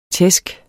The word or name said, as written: tæsk